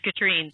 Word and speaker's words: Katrine: Actually, you're one of like 10% of people that actually say my name correctly. It's Katrine.